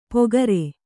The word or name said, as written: ಪೊಗರೆ